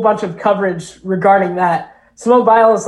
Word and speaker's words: Simone Biles: I would, what about Simone Biles? – Yeah.